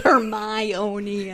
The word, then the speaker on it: Hermione: Then she tells him, Victor, how to pronounce it, Hermione.